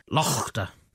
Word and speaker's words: laughter: So the word laughter is only 700 years old. And it started off with lochter.